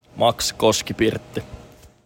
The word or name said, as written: Koskipirtti